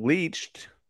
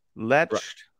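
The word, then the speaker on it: Leetch: Not Brian Leached. Letched.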